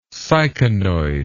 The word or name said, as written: syconoid